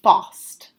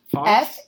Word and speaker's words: Faust: I think it's pronounced Faust. – Frust or Frost? – Faust. – Faust? – F-A-U-S-T.